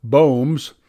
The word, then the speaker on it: Baum: The new dad brought in steady money as the superintendent and sales manager for Baums, pronounced Bohm's, Castorine Company, a family business that created lubricants for buggies and machinery, a firm that still operates out of Rome, New York.